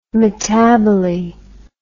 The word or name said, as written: metaboly